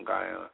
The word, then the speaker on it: Guyana: And by the way, the I said it right, Georgetown, Guyana.